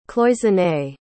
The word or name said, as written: cloisonné